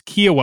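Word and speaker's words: Kiawah: And I mispronounced an island in South Carolina and quickly corrected by South Carolinians in attendance. It's Kiowa Island, in case anyone wonders. – I still don't know how to pronounce B-E-A-U-F-O-R-T.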